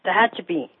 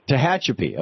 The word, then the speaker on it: Tehachapi: Anyhow, Tehachapi, California. Am I saying that right? I don't think so. – Now close enough. Tehachapi. – Tehachapi.